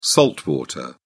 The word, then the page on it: salt water: British English